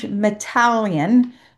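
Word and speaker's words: Mateljan: And when I was on that show, I interviewed a gentleman named George Metallion, rhymes with Italian.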